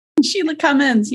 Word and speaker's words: Shelagh Cummins: Shelagh Cummins, yes.